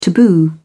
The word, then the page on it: taboo: British English